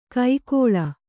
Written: ಕೈಕೊಳ್

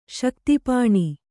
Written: ಶಕ್ತಿಪಾಣಿ

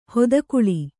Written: ಹಿತಗೊಳ್

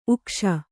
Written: ಉಗಿಸು

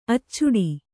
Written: ಅಚ್ಚಿಡು

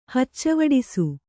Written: ಹಚ್ಚವಡಿಸು

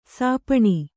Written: ಶಪನ

